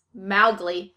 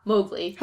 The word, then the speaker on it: Mowgli: Rudyard Kipling, the author of the book, it's actually pronounced Mowgli, not Mowgli. – Huh. – What? – Mowgli. – Yeah, so apparently Walt Disney was the one who called it Mowgli, and it's because of this movie everybody thinks of it as Mowgli.